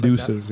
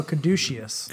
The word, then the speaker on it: caduceus: It's not pronounced caduces. – Like that. – Yeah. – No, caduceus.